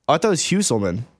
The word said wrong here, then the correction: Hulsman: Hulsman. – Okay. – Like Yule, but Hule.